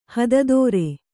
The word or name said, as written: ಹಾದಿತೋರು